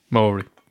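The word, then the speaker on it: Māori: I was told, pronounce it like it's almost the word mouldy. – Maori.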